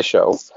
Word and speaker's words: Ajnesjo: Uh, you almost got the the uh, pronunciation of the last name correct. It's Show.